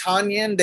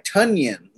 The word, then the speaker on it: Tonyan: So I think the way you pronounce it, it's not Tonyan. They said it's like Tunyan, like almost like Funyan.